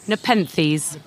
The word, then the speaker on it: Nepenthes: And the Nepenthes, did you say Nepenthes? They're the pitcher plants, aren't they? Am I saying it right? – Nepenthes.